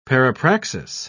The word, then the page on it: parapraxis: British English